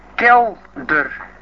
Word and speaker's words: Kelder: Kelder. K-E-L-D-E-R. – That's right. Gerard Kelder. K-E-L-D-E-R in English, we say. Anyhow, K-E-L-D-E-R of Radio Caroline, care of the Harcourt Shipping Agency, Parkston Quay, Harwich in Essex.